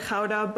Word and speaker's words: Gouda: And then I can't forget to mention Gouda, or they pronounce it Gouda, but they make everything out of this cheese, and it's delicious.